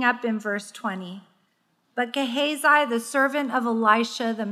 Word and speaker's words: Gehazi: My Bible calls this next passage Gehazi's greed. Gehazi, that's it. I got it.